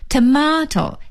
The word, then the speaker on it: tomato: tomato, T-O-M-A-T-O, tomato, 西红柿。 tomato, 美音的发音呢是念成 tomato, T-O-M-A-T-O, tomato, 所以 tomato 和 tomato 都是对的。